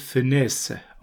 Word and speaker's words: Funès: Der Nachname wird Funès ausgesprochen. Also Louis de Funès.